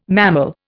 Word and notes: mammal: Indian

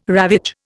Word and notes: ravage: Indian

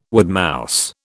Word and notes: woodmouse: British English

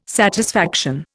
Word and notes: satisfaction: Indian

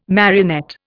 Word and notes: marionette: Indian

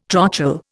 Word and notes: trochal: Indian

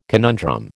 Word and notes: conundrum: American English

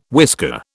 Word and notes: whisker: British English

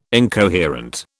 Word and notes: incoherent: British English

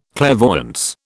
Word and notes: clairvoyance: British English

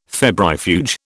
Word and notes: febrifuge: British English

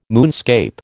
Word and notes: moonscape: American English

